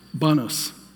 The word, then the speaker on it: Los Baños: Is it Los Banos or Los Baños, the city? Banos. Yeah, okay.